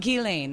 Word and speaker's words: Ghislaine: Ghislaine. – That's how you say it.